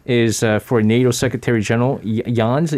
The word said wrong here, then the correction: Jens: Is that... Oh, you're talking about Jens Stoltenberg? – Oh, okay.